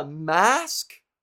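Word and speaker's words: masque: Brow pencils and you know, foundations and masks with a Q-U-E? Come on. If you send me a mask, because it's French. If you send me a mask, which is how you pronounce it if it's Q-U-E, hydrating mask, I'm not going to use it.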